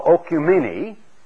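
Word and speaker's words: oikoumene: This one is pronounced almost the same as we would pronounce ecumenical, and from which we take the word ecumenical, if you understand it, and it's called ocumene, which merely means the land.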